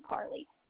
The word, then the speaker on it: Carlie: So it's for his account, it's the Jameson Company, J-A-M-E-S-O-N. – And what did you say your name was? – My name is Carly. – Is it C-A-R-L-I-E? – Yes, ma'am.